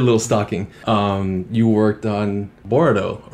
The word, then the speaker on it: Boruto: I mean, I usually just say Boruto, but yeah, Boruto. I guess if I were in Japan, yeah, I would pronounce it differently.